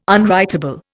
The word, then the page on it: unwritable: Indian